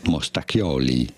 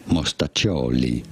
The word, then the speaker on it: mostaccioli: In Italy, in Italian, it is not said as mostacchioli, but rather mostaccioli.